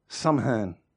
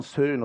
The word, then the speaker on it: Samhain: De Kelten die vierden op 31 oktober hè, eh, Samhain. Het wordt zo niet uitgesproken. Ik spreek het uit zoals je het schrijft. Het is meer van Seun of zoiets.